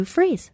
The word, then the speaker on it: Fries: And you're listening to me, Sue Freeze. And this is the Sue Freeze Show. And it's not spelled F-R-E-E-Z-E. It's spelled F-R-I-E-S.